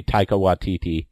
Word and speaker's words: Taika Waititi: And then the other thing I'll pitch, I'm a big Taiki Waititi. My daughter's always make fun of the way I say, I think it's supposed to be Taika Waititi.